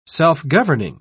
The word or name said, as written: self-governing